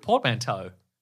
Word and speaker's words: portmanteau: Portmanteau.